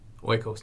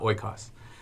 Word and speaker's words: oikos: I think one of us was pronouncing this word wrong in previous podcasts, but we now know it is pronounced oikos. – Yes, oikos, not oikos.